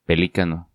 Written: Pelicano

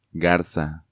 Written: Garza

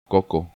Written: Coco

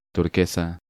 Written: Turquesa

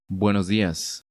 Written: Buenos dias

